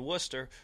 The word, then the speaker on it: Worcester: Yeah, and that's one of those kind of cool things about the Worcester Red Sox here is that, you know, again, you said it right. We are the Worcester Red Sox.